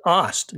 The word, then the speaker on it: Ast: Thank you for correcting my pronunciation. It's Ost, Nick Ost. – Yeah, weird pronunciation. Looks like Ast, just spelled A-S-T.